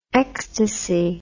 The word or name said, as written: xtc